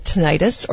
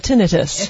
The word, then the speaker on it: tinnitus: Okay, first of all, is it tinnitus or tinnitus? – It's actually pronounced both ways.